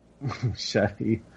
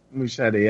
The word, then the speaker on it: Muschietti: All we really got this week is, the director for the Flash movie, Andy Muschietti. Is it Muschietti? Muschietti? Yes. Is it Muschietti or is it Muschietti? – Muschietti. – It's Muschietti? – Muschietti, yeah.